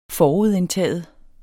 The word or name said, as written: forudindtaget